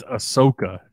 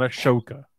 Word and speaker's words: Ahsoka: It's Ahsoka, not Ahsoka. – Whatever, Ahsoka. – Man. – Ahsoka. – There's an H in there. – It is spelled the way you're saying it, but that's just not how it's said.